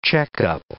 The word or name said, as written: checkup